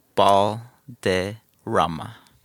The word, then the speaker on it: Balderrama: Balderrama. – Balderrama. – There it is. – Okay, I had it right. I didn't want to butcher it. – You could roll your R's too if you want.